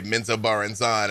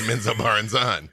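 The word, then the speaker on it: Menzoberranzan: And then he proceeded to say Minzo Barinzan, and I'm like, well, then that's how you say it, Bob. That's it. You just did it. Like, you literally made the thing, ergo, vis-a-vis, and concordantly, it is Minzo Barinzan.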